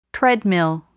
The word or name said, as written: treadmill